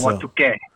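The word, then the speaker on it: Ahwatukee: Awatkui. It's a suburb of Phoenix on March the 22nd. – And it's Awatuke. – Awatuke? Is that how you say it? – Yeah.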